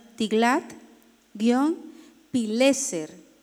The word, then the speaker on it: Tiglat-Pileser: El rey de Asiria de ese entonces se llama Tiglat, como suena, Tiglat-Pileser. Tiglat-Pileser, con acento en la E, con tilde en la E, Pileser, tercero.